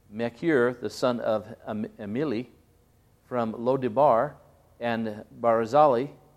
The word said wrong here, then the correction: Barzillai: let me pronounce that a little better, let's see, Barzillai,